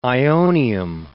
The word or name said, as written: ionium